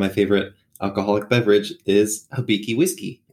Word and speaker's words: Hibiki: So tell us a little bit about, I was reading on your Twitter today, something about, is it Hibiki whiskey? Or how do you pronounce it? – Oh, Hibiki. Yeah. Hibiki.